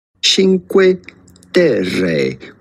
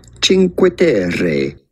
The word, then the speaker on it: Cinque Terre: Cinque Terre, with the typical Italian pronunciation, Cinque Terre.